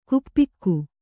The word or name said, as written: ಗೋಪಿಕೆ